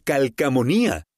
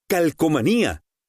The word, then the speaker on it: calcomanía: ¿Cómo se dice correctamente? ¿Calcomanía o calcamonía? De acuerdo con la RAE, la forma correcta es calcomanía. Con O, calcomanía.